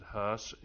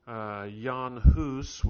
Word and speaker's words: Hus: We usually pronounce it Huss, English as we anglicize it. Jan Hus was the original pronunciation.